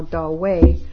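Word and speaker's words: Dahui: And her present focus of study is translation of the letters of Zen master Da Hui, pronounced actually now Da Wei.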